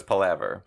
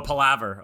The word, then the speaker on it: palaver: Is it palaver? – The audiobook says palaver. – Oh, palaver. Okay.